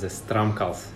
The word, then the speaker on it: Stramkals: Last name is Stramkals.